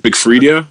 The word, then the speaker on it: Big Freedia: She put me on to artists like Big Freedia. I don't know how to pronounce. Is it Big Freedia?